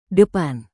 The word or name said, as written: depan